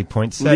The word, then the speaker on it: poinsettia: Legally, poinsettia. – Legally, it's poinsettia. – Yeah. – But it's just that extra syllable in there. The poinsettia is just, you get lazy. It takes too long.